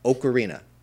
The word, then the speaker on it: ocarina: If you're going to make an O sound, that's an O. So it is pronounced ocarina.